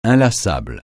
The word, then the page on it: inlassable: francais